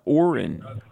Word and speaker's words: Oren: In Louisiana, Oren, O-R-E-N, am I pronouncing your name correctly, Oren? – Yes, sir, sure are.